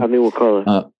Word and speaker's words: Wirkola: Wirkola? Yeah, Tommy Wirkola.